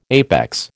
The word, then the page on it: APEX: American English